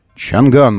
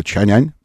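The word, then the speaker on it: Changan: Чанган мы называем ее. Но они хотят, чтобы это было Чанянь произносилось.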